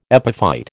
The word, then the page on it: epiphyte: American English